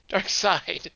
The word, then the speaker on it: Darkseid: Darkseid. I mispronounced it. It's Darkseid.